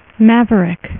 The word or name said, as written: maverick